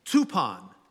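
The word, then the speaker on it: tupon (τύπον): The Greek word is tupon, T-U-P-O-N. Tupon rhymes with coupon.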